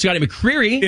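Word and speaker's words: Scotty McCreery: I believe it's pronounced Scotty McCreary.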